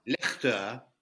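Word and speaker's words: Leichte: Ooh, excellent pronunciation there. – Lechte. Thank you. The Lechte just means lighter.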